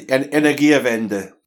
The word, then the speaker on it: Energiewende: the Energiewende, Energiewende. – Yeah, the Energiewende.